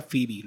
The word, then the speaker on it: Phoebe: Eh, viene otro especial que es de, no sé si este nombre lo estoy diciendo bien, es Phoebe Robinson, pero no sé si se, de verdad se pronuncia Phoebe lo que estoy leyendo. – P-H-O-E-B-E. E. Sí. – Ah, pues mira, lo dije bien.